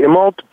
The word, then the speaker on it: Immelt: Am I pronouncing it right? Immelt or Immelt? How do you pronounce it? – I would say Immelt, but I think he would be fine with Immelt also.